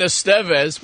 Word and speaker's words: Estevez: Every story involves, like, oh, me and Estevez, which is how you pronounce it, apparently.